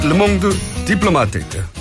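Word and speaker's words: Le Monde diplomatique: 자, 르몽드 디플로마테이트. 어떻습니까, 제 발음? 네, 완벽합니다. 완벽이 하겠습니까만은.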